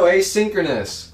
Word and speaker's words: asynchronous: Well, asyncratic? Is that how you say it? – No, asynchronous.